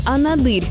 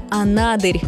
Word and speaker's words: Анадырь: Часто можно услышать вариант «Анадырь». И это ошибка. Правильно, Анадырь, с ударением на второй слог. И здесь важно учитывать происхождение названия. Оно связано с языками коренных народов Чукотки, и в оригинальном звучании ударение закреплено именно на «А».